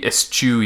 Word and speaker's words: eschew: So we eschew you. Did I pronounce that right? – Eschew? – Yeah. We eschew, like, you. Cashew.